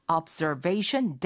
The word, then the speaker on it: observation: observe를 하는 그런 데크다라고 해서 observation deck. 라고 말하게 됩니다. – Observation deck. – Observation deck. – Sure. – 이거 옵저베, 옵저, 앞저 이렇게 말씀하시면 잘 못 알아듣고요. zer 해서 z 발음이 됩니다.